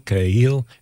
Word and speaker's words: Kahil: That's spelled K-A-H-I-L, Khalil, and then E-L apostrophe Z-A-B-A-R.